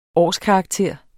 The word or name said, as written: årskarakter